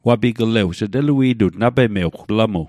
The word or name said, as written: wapigle'wj